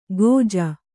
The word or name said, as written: ಖೋಜಾ